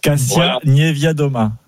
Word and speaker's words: Kasia Niewiadoma: Et surtout la bataille pour le général, la polonaise dont je n'arrive pas à prononcer le nom, Kasia Niewiadoma.